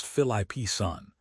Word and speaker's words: Phillipson: Meanwhile, in the UK, Education Secretary Bridget Phillipson, pronounced Philippson, is pushing an interventionist agenda based on the idea of education as freedom.